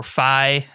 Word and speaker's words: Fi: I like that he chimes in every once in a while, very Fee-esque or Fi, as Cody likes to correct my pronunciation.